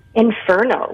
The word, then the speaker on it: Inferno: Infermo. How do you say that? – Inferno, like fur of a cat.